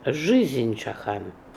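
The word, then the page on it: Lifechakan: Russian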